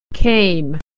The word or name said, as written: came